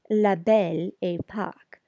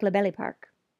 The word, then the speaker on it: La Belle Époque: Just by way of personal anecdote, it's La Belle Époque, and it's generally pronounced La Belle Époque, but when said quickly, it can sound like La Belle Époque.